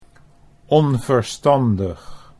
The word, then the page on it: onverstandig: Dutch